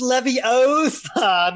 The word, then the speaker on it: Leviosa: Gabrielle's involvement is kind of like Hermione in wand class, where she's like, it's Leviosa, not Leviosa.